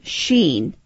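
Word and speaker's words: Shin: Now, this same initial, this same sheen that is pronounced with an SH sound, is actually embedded into Jerusalem with the three valleys.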